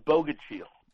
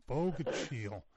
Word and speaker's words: Bogachiel: It's Bogachiel. – Bogachiel.